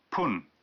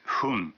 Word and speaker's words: 分: Se pronuncia... pun, en vez de fun.